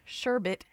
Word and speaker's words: sherbet: Friends of ice cream, I would say, include custard, gelato, and frozen yogurt, also sorbet or sherbet. And yes, that's how it's pronounced. I looked it up. It sounds really weird, but there you have it.